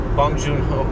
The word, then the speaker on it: Bong Joon-ho: Bong Joon-ho.